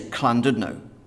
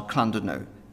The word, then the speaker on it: Llandudno: The Welsh people, they've got this double L. And when you see a double L, it's a cull sound. So you just say Llandudno, Llandudno. It's, you know, it's not hard, Llandudno. That's how you say it. And I kind of piped up and was like, well, it is a double L writing, but it's a sound. It's not a sound that you're used to, it's a sound.